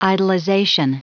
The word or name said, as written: idolization